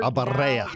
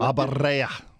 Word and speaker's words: Abarrea: You guys say it flawlessly. You yes. – Even roll your R's. – Abarrea.